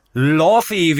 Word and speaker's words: Laufey: Lofi, wir wissen nicht genau, wie sie ausgesprochen wird, weil sie ist Tochter eines isländischen Vaters und einer chinesischen Mutter. Lofi wird das wahrscheinlich ausgesprochen. Irgendwas, das A ist irgendwas zwischen A und O, auch gerne mal im Isländischen. Es wird auf jeden Fall Laufey geschrieben, also L-A-U-F-E-Y, falls ihr euch da weiter informieren wollt.